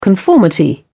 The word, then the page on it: conformity: British English